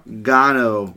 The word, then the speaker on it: Gano: All right, so starting off the list, we have Graham Gano. Rhymes with Guano.